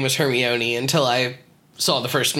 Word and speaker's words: Hermione: it's pronounced Hermione, not Hermione.